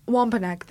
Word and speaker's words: Wampanoag: Wampanoag, thank you. – That might be it, I'm pretty sure. – Wampanoag. So Wampanoag is a tribe, a Native American tribe. So in Wampanoag culture, Pukwudgies are these spirit-like creatures known for tormenting and murdering the people that they lure into the forest.